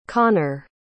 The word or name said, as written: conner